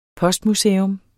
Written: postmuseum

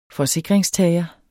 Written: forsikringstager

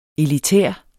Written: elitær

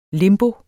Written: limbo

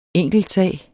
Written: enkeltsag

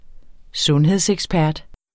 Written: sundhedsekspert

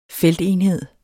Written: feltenhed

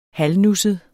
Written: halvnusset

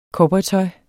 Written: cowboytøj

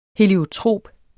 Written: heliotrop

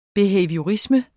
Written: behaviorisme

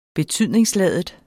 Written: betydningsladet

